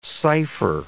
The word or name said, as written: cipher